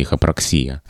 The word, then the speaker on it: эхопраксия: Поэтому здесь, наверное, можно поверить фиксации в медицинских изданиях, где это слово дается с ударением на «и», «эхопраксия».